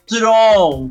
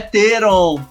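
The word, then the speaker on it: Theron: Tô falando de Mad Max, Estrada da Fúria, com a nossa queridíssima Charlize Tron, porque ela mesma disse que é o nome dela se pronuncia assim, Charlize Tron, Não é Teron, tá?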